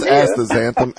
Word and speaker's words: astaxanthin: Pronounce astaxanthin. How do you pronounce that? – Astaxanthin. So instead of X, put a Z there in your mind.